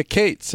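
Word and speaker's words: Kaats: So it was pronounced Cates for many generations prior to me saying Cates.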